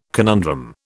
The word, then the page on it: conundrum: British English